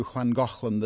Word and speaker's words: Llangollen: It's not spelled like it sounds. It's Llangollen. I called Wales this morning to confirm the pronunciation.